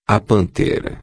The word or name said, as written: pantera